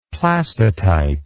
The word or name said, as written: plastotype